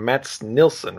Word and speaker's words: Mats Nilsson: Mats Nilsson, right? – Yep.